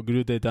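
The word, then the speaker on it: Groeten uit: Yo busqué cómo se pronunciaba porque dije, no se debe pronunciar así ni en pedo. Ah, y es algo así como Groetet Amsterdam.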